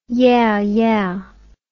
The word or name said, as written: yeahyeah